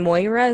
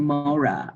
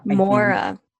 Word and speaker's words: Mora: So Cyclone Moira, is that what you said? – Cyclone Mora. – Mora. – Is how you pronounce it, M-O-R-A.